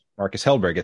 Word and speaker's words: Marcus Hellberg: And did I butcher your name in that pronunciation? – No, not at all. Yeah, so my name is Marcus Hellberg.